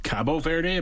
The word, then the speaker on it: Cabo Verde: Same thing with the team Cape Verde. – It's pronounced Cabo Verde, apparently.